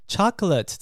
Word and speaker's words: chocolate: Chocolate 这个字，它不是念 chocolate，虽然是 l a t e 结尾呢，可是它是念呃的音。 Chocolate，chocolate，好，巧克力，chocolate。